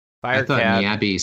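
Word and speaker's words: Niabe (ニャビー): Niabe is believed to be a cat, rhymes with tabby.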